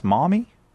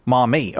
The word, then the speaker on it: Maumee: We go now to Maumee, Ohio. Did I pronounce it correctly? Maumee? – Maumee. – Mommy.